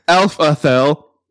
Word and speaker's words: Alphedhel: So she gave me a phonetic pronunciation. Okay. Alphethel, spelled Alphedhel.